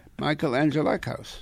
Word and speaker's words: Angelakis: I said it correctly, you out there. Michael Angelakis. – Yeah. – Is that not right? – If Michael's listening on his way here, he's going to hear that you have said his name correctly.